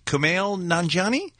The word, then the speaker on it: Kumail Nanjiani: Is it Kumail Nanjiani? – Pretty close.